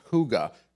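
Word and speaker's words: hygge: It's a Norwegian word, and it's pronounced "huga."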